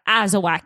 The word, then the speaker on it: Azawakh: It's called Azawak. – Azawak. – Actually, pretty sure I am saying that right. Azawak. It's spelled Azawakh.